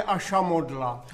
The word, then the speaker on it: ašamodla: A to se řekne ašamodla. – Ašamodla. – No ano, i výslovnost máte dobrou.